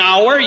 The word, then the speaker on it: hour: And that's the reason why you have H in front of O-U-R. 60 minutes in an hour, you could spell it O-U-R if you wanted, but hour, an hour, and any other word that is aspirated, the H is an aspiration letter.